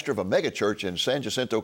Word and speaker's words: San Jacinto: It's a great pronunciation on the name, by the way. – Jacinto. Thanks San to your Jacinto. coaching. Yeah, San Jacinto.